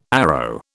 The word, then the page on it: arrow: British English